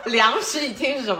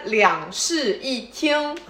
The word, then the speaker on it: 两室一厅: 哦，那两室一厅的听众朋友，你们好。 – 梁，等会儿，等会儿，等会儿，梁室一厅是什么东西？ – 你们不是两室一厅吗？ – 是，对，但是它发音不应该两室一厅。